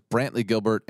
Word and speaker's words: Brantley Gilbert: So this is Brantley Gilbert and Carly Pierce.